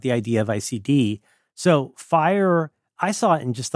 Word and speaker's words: FHIR: And you may be excited to know that FHIR is pronounced fire. Nobody reads the letters out.